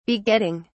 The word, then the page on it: begetting: British English